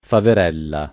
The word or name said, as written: faverella